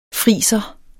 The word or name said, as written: friser